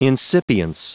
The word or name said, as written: incipience